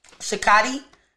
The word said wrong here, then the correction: Shakti: Shakati energy. Shakti. Shakti energy to rise from the from my from the base of my spine and open up to the universe Wait, start over.